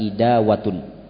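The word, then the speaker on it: idawatun (إداوة): Kemudian yang kedua, lafaz asing berikutnya pada hadis ini, idawatun min ma'in, sebuah bejana yang berisi air, bikasril hamzah, ya, cara membacanya dengan dikasrokan hamzah, idawatun, ya, idawatun.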